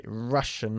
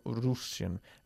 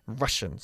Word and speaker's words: Russian: Russian, not Russian. Russian, se pronuncia.